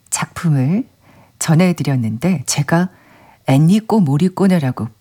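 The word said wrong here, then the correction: 엔니오 모리코네: 발음을 했답니다. 더위를 먹은 걸로. 죄송합니다. 이민하님, 애니오 모리꼬네가 맞습니다.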